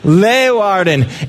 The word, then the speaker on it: Leeuwarden: In 2009, in, I do not know how to pronounce Dutch things, but in a city called Leeuwarden. Leeuwarden. How do you pronounce that? What he said. All right. Leeuwarden.